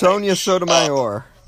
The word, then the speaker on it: Sonia Sotomayor: just the idea of somebody, because not only will Ginsburg go, but Sotomayor, I don't know how to pronounce it. Sonia Sotomayor.